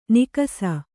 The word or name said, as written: ನಿಕಸ